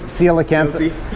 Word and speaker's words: Coelacanth: They can learn to pronounce Coelacanth. Right? So it's Coelacanth.